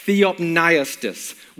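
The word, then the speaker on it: Theopneustos (θεόπνευστος): The earliest translation that we have of God-breathed is pronounced Theopneustos, which literally means breathed out by God.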